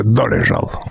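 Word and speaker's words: Dolezal: Зовут ее Рэйчел Долзл. О том, что она белая, вдруг заявили ее родители. Кстати, имя, которое американцы произносят здесь как Долзл, это, несомненно, чешское Долежал.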